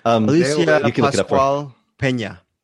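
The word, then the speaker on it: Alicia Pascual-Peña: And there's a brand new girl in school named Lucy, played by Alicia Pascual Peña. I'm saying it wrong. All right. – Alicia Pascual Peña. Peña, thank you.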